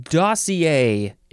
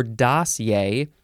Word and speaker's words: dossier: Dossier is next. You can pronounce it two syllables or three syllables. So we got dossier or dossier, or three syllables, which is dossier or dossier.